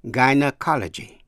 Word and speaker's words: gynecology: Ngoại lệ nữa là G-Y phát âm là Guy trong chữ gynecology. G-Y-N-E-C-O-L-O-G-Y. Gynecology là môn sản phụ khoa, bệnh phụ nữ.